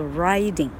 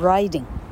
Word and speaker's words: riding: 好，于是子 I N G 进入第三个原则，元音重现，这个字要念作 riding。 I 要读作 I，变成 riding。